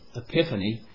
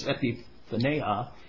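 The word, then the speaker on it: epiphaneo (ἐπιφάνεια): We normally pronounce that epiphany, but it's epiphaneo.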